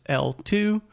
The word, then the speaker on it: eltoo: Yeah, it's spelled ELTOO. – Right. – It's pronounced as L2.